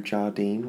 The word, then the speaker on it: Jardine: At Andrew Jardine, which is spelt like sardine but with a J on the front.